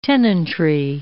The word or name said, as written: tenantry